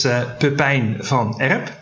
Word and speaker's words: Pepijn van Erp: But my name, if properly pronounced, is Pepijn van Erp.